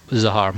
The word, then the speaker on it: Zahar: Zahar, Mahmoud Zahar.